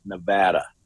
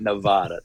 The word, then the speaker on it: Nevada: If you ever want to feel like a local in Las Vegas, you have to pronounce it Nevada. Nevada. It's not Nevada.